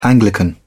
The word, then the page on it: anglican: British English